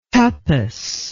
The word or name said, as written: tapas